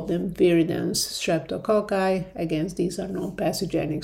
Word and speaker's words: viridans: And there is a word for it, that they are called viridans group streptococci, VGS. Viridans is spelled V-I-R-I-D-A-N-S, and this is really a definition that is kind of not that used anymore.